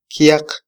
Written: kyaq

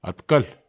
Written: atkel

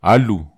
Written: alu

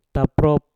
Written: taprop

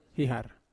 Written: hahar

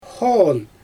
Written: xoon

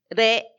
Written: re

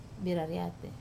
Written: rariat

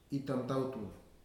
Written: itamtautu

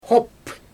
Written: xop

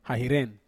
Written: hahiren

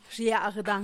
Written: xiagdan

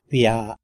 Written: bya

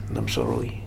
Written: Namsaroi

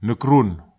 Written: nekrun

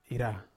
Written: ra

